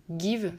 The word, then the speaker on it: Geev: Il y a une application qui s'appelle Give, ça s'écrit G-E-E-V.